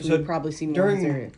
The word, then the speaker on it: Azaria: Azaria.